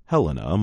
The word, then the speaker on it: Helena: Helena, Montana. The capital city of Montana is pronounced Helena, not Helena. That is how most women named Helena say their name.